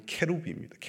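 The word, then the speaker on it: cherubim: 원래는 발음이 캐루빔입니다. 캐루빔. 캐루브 복수가 캐루빔 이렇게 되는 것이죠.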